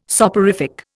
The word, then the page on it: soporific: Indian